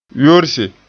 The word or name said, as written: yorse